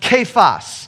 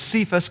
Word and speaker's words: Kephas: Peter is a Greek word that translates the nickname Rocky, which in Aramaic is pronounced Kephas. So in Aramaic, his name was Kephas. Sometimes people pronounce it as Cephas because it's spelled with a C at the beginning.